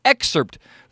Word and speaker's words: excerpt: maybe a paragraph or excerpt from the book before we get to our guests. – You even pronounce excerpt with the P.